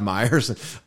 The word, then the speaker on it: Meyers: And if they get their phones out, he could become viral and then get invited to Saturday Night Live and Seth Meyers. And you know, Seth Meyers rhymes with I'm on fire, which is the...